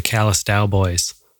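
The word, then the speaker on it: Callous Daoboys: But we've got the new record Celebrity Therapist by the Callous Dow Boys. – Yeah, you say it like the Dallas Cowboys, but switched around.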